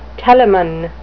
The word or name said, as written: telamon